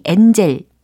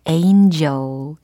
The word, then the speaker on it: angel: 그리고 천사에 해당하는 단어 발음이 angel 이게 아니라 angel 이렇게 발음을 해주셔야 되겠죠.